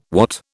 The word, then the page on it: watt: British English